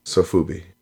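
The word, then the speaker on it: Sofubi (ソフビ): Sofubi, yeah. – It's Sofvi, so how do you get Sofubi? – Because that's not how it's spelled, what you just said.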